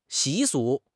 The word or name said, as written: 习俗